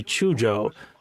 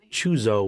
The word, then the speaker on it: Chujo: The newspaper gag here is a rather clever and playful way to introduce the second hero of our story, Dr. Shinichi Chujo, sometimes pronounced Chuzo with a Z in the English version.